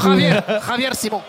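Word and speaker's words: Javier Simón: Xavier Simon est un petit peu. – Non, Javier Simon.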